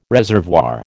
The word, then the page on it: reservoir: American English